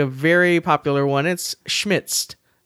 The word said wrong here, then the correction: Schmidt's: Schmidt. Yes. Now I can't say it. Thanks. SCHMIDT'S. There you go.